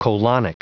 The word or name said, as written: colonic